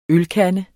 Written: ølkande

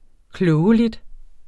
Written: KLOGELIGT

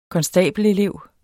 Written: konstabelelev

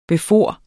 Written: befare

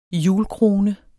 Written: hjulkrone

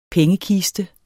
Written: pengekiste

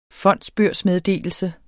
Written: fondsbørsmeddelelse

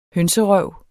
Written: hønserøv

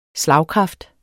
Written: slagkraft

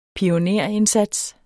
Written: pionerindsats

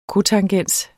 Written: cotangens

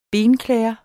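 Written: BENKLÆDER

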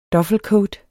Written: duffelcoat

